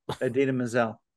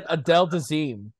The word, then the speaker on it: Idina Menzel: He said Adele Dazeem. it's just like, uh, did John Travolta just have a stroke on national TV? Because that's not anywhere close to Idina Menzel.